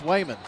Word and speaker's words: Wayman: I feel like I'm Elmer Fudd when I say his name, but it is Wayman.